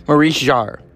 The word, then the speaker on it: Maurice Jarre: Maurice Jarre.